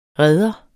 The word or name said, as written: redder